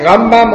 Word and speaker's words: Rambam: Der heißt Rambam und der heißt Ramban. – Betonung ist wichtig.